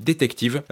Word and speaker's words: DTCV: Et dans l'ordre, donc, il y aura les Californiens de DTCV, ça s'écrit DTCV en lettres majuscules, mais ça se dit Détective, un très bon groupe garage californien dont la chanteuse Lola est une expatriée française.